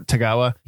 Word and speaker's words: Tagawa: Tagawa.